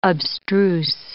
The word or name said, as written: abstruse